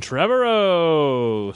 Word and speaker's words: Trevorrow: First up, Jurassic World, directed by Colin Trevorrow. I say Trevorrow. – I say it like tomorrow.